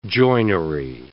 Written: joinery

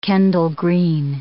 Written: Kendal green

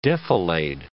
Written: defilade

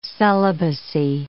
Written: celibacy

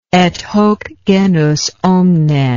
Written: et hoc genus omne

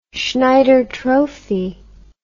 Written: schneider trophy

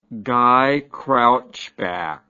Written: guy crouchback

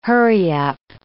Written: hurry-up

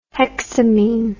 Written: hexamine

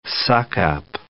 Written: suck-up

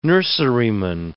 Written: nurseryman

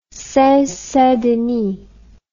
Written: seine saint denis

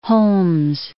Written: Holmes

